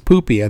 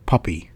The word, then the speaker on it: Pupi: I don't think it's Poopy. I think it's Pupi.